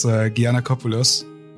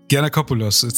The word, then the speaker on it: Giannakopoulos: Okay, It's rather hard to pronounce. – Is it? – Yeah, it's Greek. It's Giannakopoulos. – Sorry? – Yeah, it's Giannakopoulos.